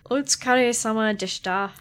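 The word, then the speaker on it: Utsukaresama deshita (お疲れ様でした): Otsukaresamadaishita. – Come on, can you not phonetically pronounce Japanese? – I'm tired. – Give it a go. – Utsukaresama deshita. – There you go.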